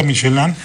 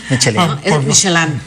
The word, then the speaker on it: Michelin: Oye, que esa es la pronunciación correcta, porque todo el mundo, la guía Michelin, Michelin, ¿no? Michelin. Sí, por ser francés, pero claro, al final ya estamos en México.